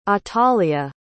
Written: atalia

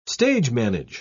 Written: stage-manage

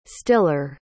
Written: stiller

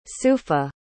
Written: sufa

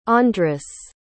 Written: andras

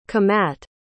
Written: qimat

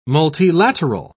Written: multilateral

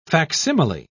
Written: facsimile

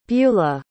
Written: buelah

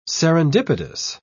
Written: serendipitous

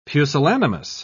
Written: pusillanimous

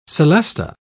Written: celesta

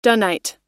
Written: dunite

